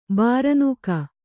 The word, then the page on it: ಬಾರನೂಕ: bāranūka